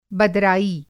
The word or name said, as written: बदराई